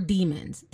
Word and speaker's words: daemon: called daemons or demons, depending on how you want to pronounce it, when I was in IT, a lot of the guys would say Demon just to, like, you know, creep me out. It's spelled Damon, D-A-E-M-O-N, but a lot of people use the word interchangeably with Demon.